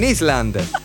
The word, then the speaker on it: island: He was living on an island. Ecco, island non si dice, si dice island.